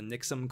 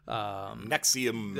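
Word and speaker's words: NXIVM: The Nixum cult? – Nexium.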